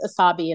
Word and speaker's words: Asabi: Because I'll say, oh, my name is Asabi, and I'll get something like Asami, or there's some other name that they repeat, Asani. And I'll say, no, Asabi, like wasabi without the W.